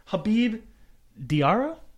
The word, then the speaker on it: Diarra: Habib Diarra? Would that be how you pronounce that? The new Sunderland? – Yeah.